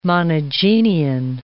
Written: monogenean

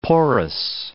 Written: porous

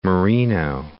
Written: Moreno Valley

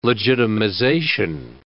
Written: legitimization

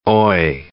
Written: oy

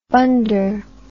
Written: bunder

